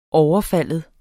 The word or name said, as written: overfalde